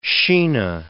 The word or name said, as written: Shina